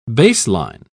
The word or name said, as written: baseline